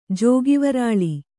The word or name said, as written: ಜೋಗಿವರಾಳಿ